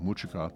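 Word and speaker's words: Mutcika: Before that, Ronnie Earl gave us Muchika, and that's spelled M-U-T-C-I-K-A. I give it a soft C, so I say it Muchika, and that was from his release of several years ago, I Like It When It Rains.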